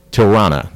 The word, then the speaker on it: Tilarna: One policeman, Kay Matoba's partner, is killed during a drug bust by a Samanian, leading to getting a new partner, Tilrana. That's how it's pronounced. It's spelled T-I-L-A-R-N-A, but Japanese, it's pronounced the R's. The L's spelled like pronounced like an R.